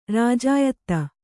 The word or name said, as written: ರಾಜಾಯತ್ತ